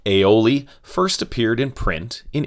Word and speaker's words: aioli: Cooks in Provence combined the French words for garlic and oil to form, as we pronounce it in English, aioli.